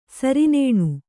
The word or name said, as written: ಸರಿನೇಣು